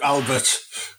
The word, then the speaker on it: Albert: It is Albert. It's Albert. It's Belgium. It's pronounced Albert.